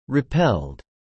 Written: repelled

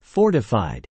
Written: fortified

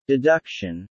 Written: deduction

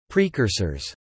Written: precursors

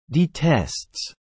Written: detests

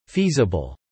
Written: feasible

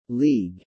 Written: league

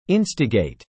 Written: instigate

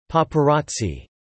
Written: paparazzi

